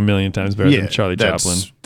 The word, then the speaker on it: Chaplin: Chaplin.